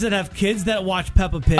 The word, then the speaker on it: Peppa Pig: It's Peepa.